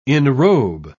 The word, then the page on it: enrobe: British English